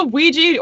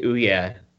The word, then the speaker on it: Ouija: I always want to pronounce it Ouya, like the console. – Yeah. Ouya. – It looks like Ouya, not Ouija.